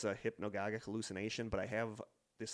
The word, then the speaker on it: hypnagogic: Hypnopompic and hypnagogic.